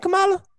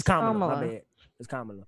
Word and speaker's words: Kamala: That's not how you say her name. – It ain't Kamala. It's Kamala, my bad.